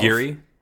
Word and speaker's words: MacGarry: Pat McGarry? – McGarry. M-A-C-G-A-R-R-Y.